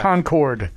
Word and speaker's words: Concord: How do you say the town where he's from? – Concord, yes, Concord.